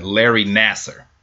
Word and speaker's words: Nassar: Larry Nassar.